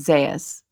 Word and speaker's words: Zayas: It's Zayas.